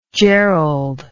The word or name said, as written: jerold